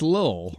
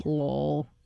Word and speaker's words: Lil: You also correctly did not pronounce the T's in Little Kim. – Well, because it's Lil. – Lil. – Yeah. – Like Little John is a different person than Lil John.